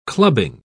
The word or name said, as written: clubbing